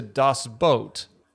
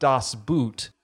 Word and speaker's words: Das Boot: Though actually pronounced closer to Das Boot, not Das Boot, the drinking game Das Boot requires a tall boot-shaped glass or Bierstiefel to be downed by the anchor of a four-person team.